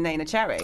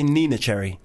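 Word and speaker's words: Neneh Cherry: And also, I just got to applaud you for the way you pronounce Nana Cherry, because most people say Nina Cherry. – Oh, no. Nina? – A lot of people say, honestly, a lot of, you'd be surprised how many people pronounce her name Nina Cherry.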